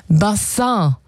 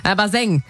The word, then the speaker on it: Bassin: Ja, es kommt eigentlich aus dem Französischen. Bassin. Und bedeutet Becken oder Wasserbecken. Das kam so, das kam so im 18., 19. Jahrhundert hier rüber nach Deutschland. Wie viele andere Begriffe aus dem Französischen damals. Und naja, die Aussprache, die hat ein bisschen gehapert. Also statt Bassin wurde es irgendwann Baseng.